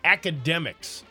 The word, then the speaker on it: Akademiks: But DJ Academics. Okay. It's spelled AKADEMIKS.